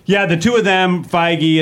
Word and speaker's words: Feige: Kevin Feige. – Well, maybe Feige? Is that how you pronounce it? Feige? – Yeah, Feige. Yeah.